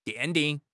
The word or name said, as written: 奠定